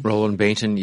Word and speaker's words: Roland Bainton: No, I did read the Here I Stand by, I don't know how to say his name, Roland Bainton. – Roland Bainton, yes.